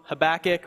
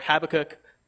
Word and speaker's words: Habakkuk: So I might say both of them, Habakkuk or Habakkuk. I don't know. You'll get it. They're both right, but I don't know.